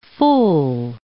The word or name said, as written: fool